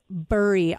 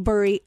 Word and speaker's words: Bury: My name is Shug Burry. I'll spell it for you. So when you write into the studio, B-U-R-Y, Shug Burry, Women's Hot Topics.